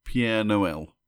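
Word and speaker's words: Père Noël: It's more like Pierre Noël. – Oh, okay. – So, I don't know how you pronounce it. It's P E with a little accent R E.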